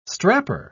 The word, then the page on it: strapper: British English